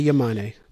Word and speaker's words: Yamane: Mickey Yamane? Is that how you pronounce his name? – Mickey Yamane.